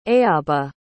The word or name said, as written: eabha